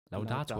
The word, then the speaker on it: Laudator: Lau, lau, wie, oh, wie, wie betont man das? – Laudator. Laudator? – Ja.